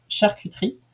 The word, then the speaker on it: charcuterie: So, since you asked so nicely, here's an audio of me saying charcuterie to you in French, which is pronounced charcuterie. The hard parts are the U and the R, since you don't exactly have those sounds in English.